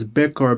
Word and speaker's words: Bekhor: But if you look at the words for blessing in in the Hebrew language, it's spelled B-R-K-H. That's the word for blessing. The word for firstborn is Bekor, B-E-K-H-O-R, which is very similar. And the word for birthright, which is Bekhora, B-E-K-H-O-R-A.